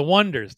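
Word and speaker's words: Oneders: So that's the O'Neaters. – Yep. – Did I mispronounce that? Oh, the Wonders.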